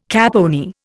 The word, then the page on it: caponier: Indian